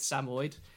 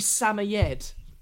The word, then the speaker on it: Samoyed: we just haven't bothered but uh, it's it's not Samoyed because uh, I'm from Sussex. I said Samoyed. Uh, it's actually Samoyed. Uh, is how you say it correctly, according to Google.